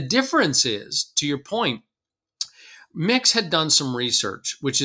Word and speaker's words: Myx: And then we met the folks at Mix, which is spelled M-Y-X,